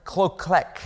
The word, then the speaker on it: kloklek: First of all, the word kloklek, it's spelled K-L-O-K-L-E-K. And you say kloklek. Let's try that together.